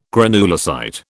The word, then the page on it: granulocyte: British English